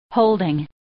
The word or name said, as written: holding